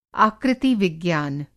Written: आकृति-विज्ञान

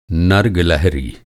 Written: नर

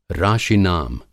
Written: राशि-नाम

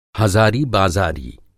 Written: हज़ारी बाज़ारी

